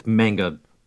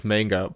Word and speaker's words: manga: Because I'm adapting to English, I say it manga, because that's how you say it with an English accent. – Uh-huh. – Which is weird, because like in French, you pronounce it in the same way as in Japanese, which is manga. Like, that's how you say it in French.